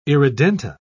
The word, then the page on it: irredenta: British English